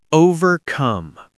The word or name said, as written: overcome